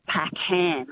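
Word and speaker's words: Packham: So my surname is Pac Ham, H-A-M, and she called me Ann Pac Man.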